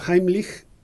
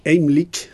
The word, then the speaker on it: Heimlich: No es un perfil, ojo, porque, por ejemplo, Heimlich. Lo pronuncia a la alemana, pero bueno. – Como debe ser. – Heimlich.